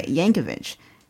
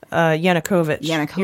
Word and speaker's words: Yanukovych: He worked for Yankovych. Is that how you pronounce it? Yankovych? – Yanukovych.